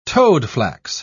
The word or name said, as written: toadflax